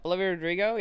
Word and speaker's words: Olivia Rodrigo: I liked when but now I'm reminded of how the NHL keeps doing fan cams, because I remember there was that Olivia Rodriguez like playoff trailer. – Ah, yes. – Yeah. – Olivia Rodrigo.